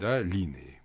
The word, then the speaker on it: Линеи: Линеи. Сложно произносить на русском языке с ударением на первый слог, но в греческом именно так произносится. Там «это» стоит ударное. Так вот, обратите внимание, Гадес, ну, Аид, владыка подземного царства, и Дионис – это одно и то же.